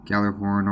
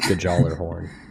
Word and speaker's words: Gjallarhorn: Yeah, kind of like the year 3 Gjallarhorn, or Gjallarhorn, if you want to pronounce that GJ as it's, I believe, intended to be pronounced in the original language. – I actually think it's Gjallarhorn. It's, yeah, neither the G nor the J are silent. You have to pronounce both of them.